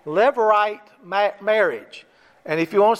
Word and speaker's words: Levirate: But it's pronounced, based I could figure out, Levirate.